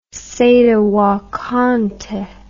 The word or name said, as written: sede vacante